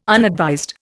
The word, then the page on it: unadvised: Indian